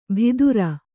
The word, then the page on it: ಭಿದುರ: Kannada